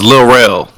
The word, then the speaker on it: Lil Rel: And don't let me forget, Little Red Howery. Is that how I say his name? Beat 'Em Down? – Close. It's Lil Rel.